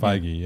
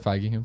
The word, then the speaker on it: Feige: How do you pronounce Kevin's last name? – We'll ask him right there and he'll tell us. – What did you say? – Feige, yeah. – And the reason I know that is because I listen to the Business, which is Kim Masters. She writes for the Hollywood Reporter and interviews him all the time, and that's how she pronounces it.